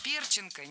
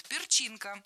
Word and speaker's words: перчинка: Перченка, неверно. Правильный вариант – перчинка, с ударением на втором слоге.